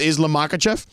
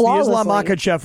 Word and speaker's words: Islam Makhachev: Were you impressed that I knew Khabib Nurmagomedov and said his name properly, along with Islam Makhachev? – Yes, the Islam Makhachev was very impressive.